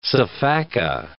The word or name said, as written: sifaka